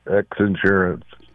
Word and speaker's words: X Insurance: You're not Zinsurance, you're X Insurance, right? – Yes, X Insurance.